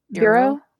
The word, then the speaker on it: Biro: Is that how you say her name? Bureau. – Bureau. B i r o, like the pen.